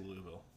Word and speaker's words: Louisville: How do you say Louisville? – Louisville. – Louisville. I think the idea is you hit the L. – Get the first letter.